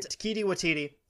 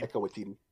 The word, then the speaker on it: Waititi: Taika Waititi, sorry, I mispronounced his name. – Taika Waititi. – Correct.